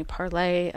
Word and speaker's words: Parler: That was originally Parlay for the French, but mostly it's pronounced Parler right now.